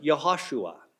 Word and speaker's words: Yahashua: And there is a name in Hebrew that is pronounced Yahashua. We pronounce it Joshua in English.